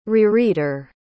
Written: rereader

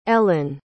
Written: elen

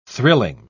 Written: thrilling

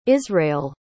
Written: israel

